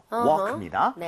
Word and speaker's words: walk: 아 요거는 묵음이에요. Walk입니다.